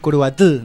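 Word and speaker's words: Curuguaty: No sé si lo pronuncio bien. – Perfecto. – Es Curuguatí, ¿no? – Exactamente.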